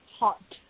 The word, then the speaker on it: Haute: And it's called Red Hout Horse. Is it pronounced Hout? Is that how you say that? H-A-U-T-E? – Hot. – Hot? Okay.